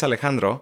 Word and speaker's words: Alejandro: So my name is Alejandro.